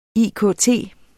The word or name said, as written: IKT